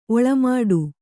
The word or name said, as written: ಒಳ್ಮಿಡಿ